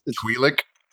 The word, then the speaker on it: Twi'lek: It's pronounced Twi'lek.